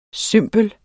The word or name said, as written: cymbel